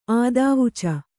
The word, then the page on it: ಆದಾವುಚ: ādāvuca